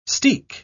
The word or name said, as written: steek